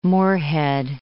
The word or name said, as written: Moorhead